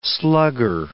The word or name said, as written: slugger